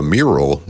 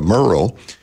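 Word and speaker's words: Murrill: But the first person she thanked was the Attorney General, Liz Merle, and she mispronounced her name. She turned her into a mural, not a Merle.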